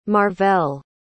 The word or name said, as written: marvelle